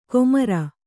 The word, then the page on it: ಕೊಮಾರ: kannada